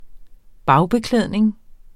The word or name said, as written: bagbeklædning